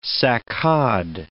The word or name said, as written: saccade